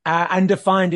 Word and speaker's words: Undefyned: So for the listeners, Undefyned is spelled U-N-D-E-F-Y-N-D. So can we jump into those two questions? – Well, yes, you had it right. I am undefined, as in the word undefined.